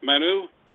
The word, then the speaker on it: Manu: But as we always ask our guests, Manu, am I pronouncing it right, Manu? – That's correct.